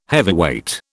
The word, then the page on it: heavyweight: British English